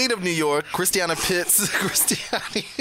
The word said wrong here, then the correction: Christiani: Christiani Pitts is the lead of King Kong.